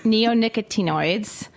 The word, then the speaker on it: neonicotinoids: Neonicotinoids.